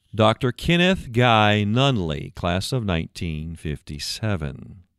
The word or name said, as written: Kenneth Guy Nunnelly